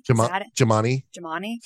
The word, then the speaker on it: Gymani: G-Y-M-A-N-I. Jemani? – Jemani.